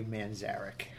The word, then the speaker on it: Manzarek: It's Ray Manzarek.